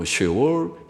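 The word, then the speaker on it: 쉐올 (שאול): 구약 성경에서는 그걸 음부라고 쉐올 히브리 말로 쉐올 우리는 스올 그렇게 번역하기도 하고 음부라고 번역한 그러나 쉐올이라고 히브리 말로 발음하는 예, 그것이 음부 쉐올입니다.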